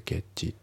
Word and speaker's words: get: であと発音ですけど get の t が抜けますね。 get じゃなくて get ですね。